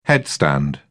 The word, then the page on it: headstand: British English